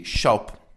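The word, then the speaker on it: Shoppe: Vamos a empezar concretamente con un grupo que se llama Curiosity Shop. Shop escrito con dos P's y E, que es un juego de letras, porque al final en inglés se pronuncia igual shop.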